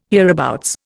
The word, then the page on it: hereabouts: Indian